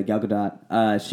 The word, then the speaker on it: Gal Gadot: But Gal Gadot, is that how you say it? – Gal Gadot? – Yeah, Gal Gadot.